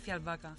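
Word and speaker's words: Fjällbacka: Comenzamos por los crímenes de Falvaca. ¿Se pronuncia así? – Eh, se pronuncia Fialbaca. – Fialbaca. – Es que es sueco. Es sueco.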